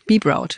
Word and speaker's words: Be Proud: Das, wie wird's korrekt ausgesprochen? – Englisch, Be Proud.